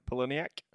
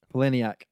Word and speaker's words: Palahniuk: Palahniuk. – Palahniuk.